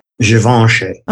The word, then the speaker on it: Givenchy: Is it Givenchy? Is that how you say it? – Givenchy.